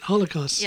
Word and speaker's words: Holocaust: Try that. Holocaust. – Yeah. Oh, yeah. That's how you say it, too.